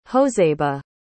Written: josebe

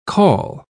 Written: caul

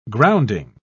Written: grounding

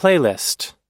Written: playlist

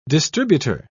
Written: distributor

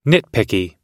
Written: nitpicky

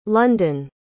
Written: londyn

